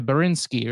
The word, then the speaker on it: Borinsky: B-O-R-I-N-S-K-Y, Borinsky, right? – Yeah, Borinsky.